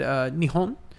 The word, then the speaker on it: Nihon: The Japanese actually pronounce it Nihon.